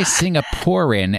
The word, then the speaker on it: Singaporean: I mean, I would say Singaporean, and my suspicion is that you are adding an extra syllable as you enjoy doing.